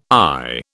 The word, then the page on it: eye: British English